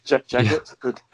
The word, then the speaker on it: Jagat: Jagat. – Yeah. I'm going to take your word on all these pronunciations. – J-A-G-A-T.